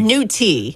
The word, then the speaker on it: nutria: All right, nudie. Am I saying it right? – Nudie. There's a T, not a D. It's not nudie. Like, new T.